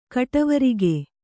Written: ಕಟವರಿಗೆ

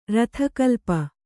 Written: ರಥಕಲ್ಪ

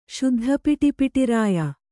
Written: ಶುದ್ಧಪಿಟಿಪಿಟಿರಾಯ

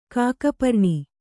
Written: ಕಾಕಪರ್ಣಿ

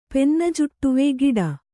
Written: ಪೆನ್ನಜುಟ್ಟುವೆ ಗಿಡ

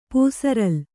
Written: ಪೂಸರಲು

